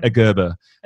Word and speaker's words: Gerber: Gerber.